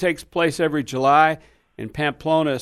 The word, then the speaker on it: Pamplona: Okay, the Pampeluma, Spain question? – Yes, sir. Wayne, here's the question. – How do you spell that town now? – It's Pamplona. – Pampeluna? – Pamplona. – Pamplona. – I think I added an extra syllable? – Vowel in there.